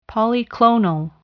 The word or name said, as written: polyclonal